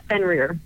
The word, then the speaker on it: Fenrir: Yeah, Fenrir.